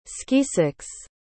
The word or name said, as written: skeesicks